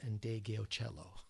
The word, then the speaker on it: Ndegeocello: And I thank Brian Saunders for the correct pronunciation of Michele Ndegeocello.